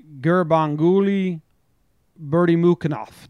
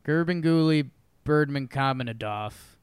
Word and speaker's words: Gurbanguly Berdimukhamedov: Yeah, I was going to say his name is Gurbanguly Berdimukhamedov. – Yeah, Gurbanguly Berdimukhamedov.